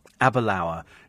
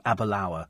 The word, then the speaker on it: Aberlour: Aberlour. It's sort of Aber as in Aberdeen and then Lower, which rhymes with tower. So Aberlour. Is that right?